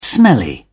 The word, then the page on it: smelly: British English